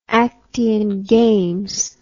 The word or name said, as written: actiangames